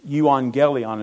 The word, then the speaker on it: Euangelion: Euangelion is how you pronounce it.